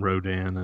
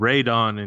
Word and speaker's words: Rodan: You know, so you learn about Rodan, and he's called Radon in Japan because they pronounce Pteranodon differently. There's, you know, and but gotcha. For the American audience, they switch the vowels, so it's Rodan.